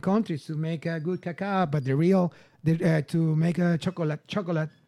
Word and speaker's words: cacao: We call it cacao. That's the way, the proper way to say it. Cacao.